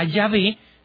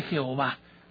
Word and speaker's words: Yahvé: Un protestante es reconocido porque le dice Jehová, ¿no? Y que es un nombre equivocado, es una colocación equivocada de las vocales.